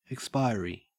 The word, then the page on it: expiry: Australian English